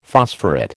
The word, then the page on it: phosphorate: American English